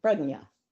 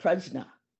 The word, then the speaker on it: prajna: And those wisdom texts lay out for us all the ultimate, that which you can't go beyond, wisdom, prajna. Some pronounce that prajna.